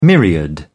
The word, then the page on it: myriad: British English